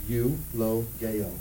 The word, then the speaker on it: eulogeo: That word blessed is a word pronounced eulogeo. Eulogeo. Can we say that together? Eulogeo. Eulogeo. That word blessed, eulogeo, also means well spoken of, or it means give thanks or praise to God.